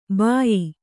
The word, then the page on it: ಭಯ್ಯಾ: bhayyā